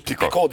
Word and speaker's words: Picot: Picot diyorlar ama Fransızca Picot diye. – T'sini telaffuz.